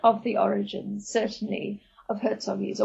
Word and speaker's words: Hertzoggies: So many people in South Africa would also say Hertzoggies. But if you're Afrikaans, and JBM Hertzog, the prime minister after whom they're named, was Afrikaans, then you would pronounce them Hertzoggies. But Hertzoggies is fine too.